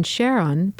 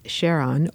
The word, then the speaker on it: Charon: When New Horizons launched in 2006, Pluto was a planet, and Charon, pronounced Charon or Charon, was its only known moon.